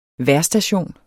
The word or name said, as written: vejrstation